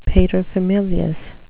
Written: paterfamilias

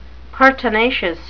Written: pertinacious

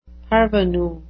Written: parvenu

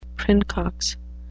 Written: princox

